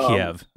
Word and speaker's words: Kiev: Maybe because it rhymes with skeeve. But I'm saying Kiev.